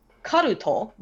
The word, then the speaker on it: Karuto: He's done two movies, one called Occult and one called Karuto, which is the Japanese pronunciation of cult, and they are two different movies, one Occult, one cult.